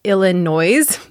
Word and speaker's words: Illinois: It's spelled Illinois, but that S at the end is silent. So Illinois.